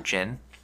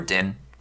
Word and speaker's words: djinn: Yeah, hopefully you learned a little bit more about djinn or djinn. They're pronounced both ways, or genie. Okay. They're all interchangeable. – Okay. – The spelling is interchangeable, D-J-I-N-N, or just J-I-N-N, or just J-I-N, or C-I-N.